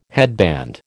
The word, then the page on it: headband: American English